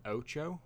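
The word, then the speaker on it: OTXO: I've been playing, not relaxing at all, to be honest, but I've been playing Ocho, I believe it's pronounced. O-T-X-O. I think it's pronounced Ocho because I saw the developers put it on his Twitter bio.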